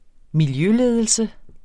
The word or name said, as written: miljøledelse